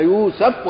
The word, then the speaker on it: يوسف: ولها طبعا نطق، احنا العوام يقول لك يوسف، هي برضو لغه فيها يوسف وفيها يوسف وفيها ايه يوسف.